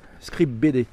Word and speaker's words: Scribd: C'est Scribe. Scribe BD. Ouais, ça s'écrit S-C-R-I-B-D.